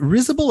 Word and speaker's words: risible: Is it risible or risable? How do you pronounce that word? – Risible. – Risible. Yeah.